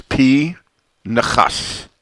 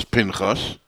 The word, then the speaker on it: Pinchas: The correct pronunciation of the name Pinchas is P. Nechas. The Nun has a Shva under it, which is a Shva Na. It does not belong to the first syllable, which is P. It belongs to the second syllable, Nechas. P. Nechas.